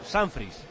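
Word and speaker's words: Sanfrecce: Y por último nos dicen que, por si nos ayuda, los japoneses se pronuncian el Sanfri, lo pronuncian como Sanfris.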